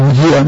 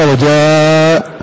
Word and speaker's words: وجيء: فهذه ألف بعد الجيم لا تقرأ ولا تنطق، يقول وجيء ما تقرأ وجاء ذا، فليحذر القارئ من أن يقرأ هكذا من تلقاء نفسه فيفتح نفسه فيقرأ هكذا.